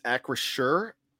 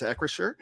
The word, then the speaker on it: Acrisure: Danny Don Hawker, I hope I pronounced that right, asked how it's pronounced, and it's Acrisure. We're pretty sure it's Acrisure.